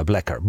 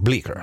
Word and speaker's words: Bleecker: No seuraava pizzeria oli sitten Johns of Bleecker, niinkö toi lausutaan vai Blacker? Bleecker.